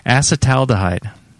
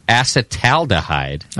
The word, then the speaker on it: acetaldehyde: Acetaldehyde. – Acetaldehyde. – Uh-huh. – It doesn't even seem like that's how it's spelled.